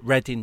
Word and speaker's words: Reading: I, I remember once there was a guy reading a newspaper at a train station, at Reading train station, and he was stood under the sign for the train station with a newspaper, and you, it's pronounced Reading, but it looks like reading.